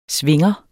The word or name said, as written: svinger